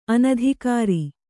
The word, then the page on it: ಅನಧಿಕಾರಿ: anadhikāri